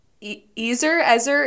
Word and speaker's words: Ezer: Ezer, it's E-Z-E-R.